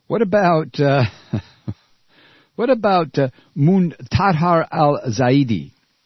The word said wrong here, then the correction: Muntadhar al-Zaidi: Muntadhar al-Zaidi.